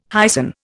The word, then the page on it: hyson: Indian